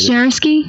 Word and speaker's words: Jarkesy: You always can get Jarkesy.